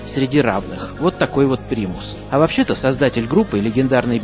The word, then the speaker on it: Primus: Чтобы не путать американцев с первопроходцами отечественного рока, нашей российской группой «Примус», название этого заокеанского ансамбля иногда произносят на англосаксонский манер, как «Primus». Но это неверно, так как слово это заимствовано не из английского языка, а из латыни.